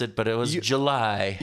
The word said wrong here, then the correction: Juli: Yeah, Juli. Juli.